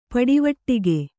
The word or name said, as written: ಪಡಿವಟ್ಟಿಗೆ